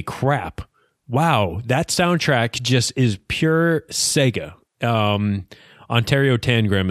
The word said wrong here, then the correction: Oratorio Tangram: No, it's like Oratorio Tangram.